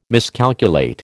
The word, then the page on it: miscalculate: American English